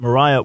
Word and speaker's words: Maria: I bring that up because there was a fiery preacher named Maria Woodworth Etter. Some would say her name is pronounced Mariah Woodworth Etter.